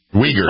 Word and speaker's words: Uyghurs: There's a great video out about some undercover journalists that go to, I'm probably mispronouncing it, Xinjiang, where the Uyghurs are. – Uyghurs. – China. – The Uyghurs. – Uyghurs. Very good. Thank you.